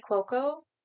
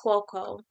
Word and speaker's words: Cuoco: I can never say her last name. It's Cuoco. – It's like Cuoco? – Yeah, it's something. – I thought it was Cuoco.